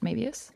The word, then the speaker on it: Moebius: Tell us a bit, for anyone who doesn't know about Vinny, Vinny is 5 years younger than Mo and has Moebius syndrome, which is that how you say it, Moebius? – That's exactly how you say it.